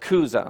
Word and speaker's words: Chuza: And we're going to pronounce it Cuza, okay? C-H-U-Z-A, Cuza. All right, so let's put those scriptures up there for us.